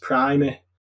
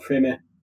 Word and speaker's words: primer: I just wanted to tell you so it's pronounced Primer, not Primer.